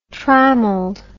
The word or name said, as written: trammeled